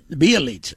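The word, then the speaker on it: Bjelica: I almost tried to say his name. – Bielitsa. – Yeah. – There you go.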